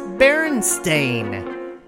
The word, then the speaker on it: Berenstain: Another weird detail to all this is if you YouTube old 80s videos and cartoons and commercials and the like, it was pronounced Berenstain. No way, right? So, welcome, folks, to Dimension B, where Berenstein is pronounced Berenstain and is spelled B E R E N S T A I N.